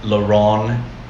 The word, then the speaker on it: Laron: Laron MC.